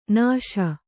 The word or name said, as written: ನುಸಿ